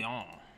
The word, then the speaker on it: Lyon: We didn't Lyon. – I'm actually happy you even pronounced it correctly.